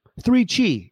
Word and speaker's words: 3Chi: 3Chi, it rhymes, is the industry leader in Delta 8 THC products.